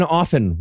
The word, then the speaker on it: often: Also, anyone who pronounces the T in often while we're on the subject.